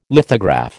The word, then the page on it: lithograph: American English